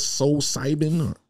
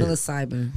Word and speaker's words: psilocybin: So I also saw a post that you were on, and you said that you tried, I'm trying to pronounce it right, psilocybin? – Yes. – How do you say psilocybin. – Psilocybin.